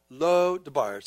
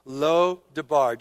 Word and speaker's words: Lo Debar: Lo Debar. So would you say that? That's a Hebrew word, Hebrew pronunciation. Would you say with me? Lo Debar.